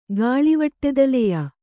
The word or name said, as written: ಗಾಳಿವಟ್ಟೆದಲೆಯ